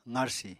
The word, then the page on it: qarsi: apatani